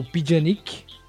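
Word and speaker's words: Pjanić: Delite, tem o Delite, né, o Bonucci, Quadrado, Alexandro, Matuidi, Janik, janik Kedira. – Kelini. – Kelini. – Tem o Pijanic. – Pijanic, é. Falei errado.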